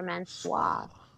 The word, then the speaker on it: Mansoor: Okay, say man. – Man. – Say swa. Swa. Now put them together. – Mansoir. I keep forgetting the swa part. I keep wanting to say Mansoor. Mansoir.